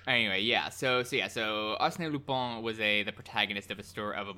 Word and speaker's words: Lupin: Arsène Lupin. – Because, so like, in French, his surname is pronounced Lupin, but in Japanese, Lupin the Third's name is pronounced Rupin, which is closer to the English Lupin.